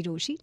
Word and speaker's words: Rochie: मेरा नाम है मिर्ची रोशी, नाम की स्पेलिंग है ROCHIE, और मेरे साथ लाइन पर है मेरी मिस्ट्री कॉलर.